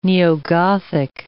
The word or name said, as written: neo-Gothic